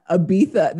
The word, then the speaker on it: Ibiza: And I also love that you did the Ibiza, the Spanish pronunciation of Ibiza.